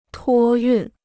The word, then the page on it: 托运: tuō yùn